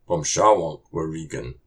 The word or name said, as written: Pômsháwôk wurikun!